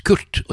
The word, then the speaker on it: QRT: Og det kan jo veldig lett uttales Kurt, og det er meningen også.